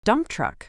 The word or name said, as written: dump truck